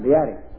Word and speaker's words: Beatty: Now, the Papyrus Codex, Chester Beatty, and don't call it Beatty, unless Beatty is your name. It's pronounced Beatty, so they tell me.